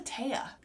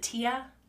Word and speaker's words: Tai: What's a Taya? Is it Tia? – No, it's pronounced Taya. T-A-I.